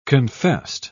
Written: confessed

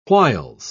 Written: whiles

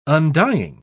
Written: undying

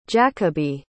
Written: jacobi

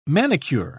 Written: manicure

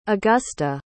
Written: augusta

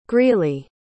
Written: greely

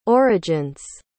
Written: origin's

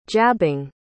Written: jabbing

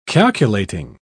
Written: calculating

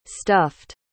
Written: stuffed

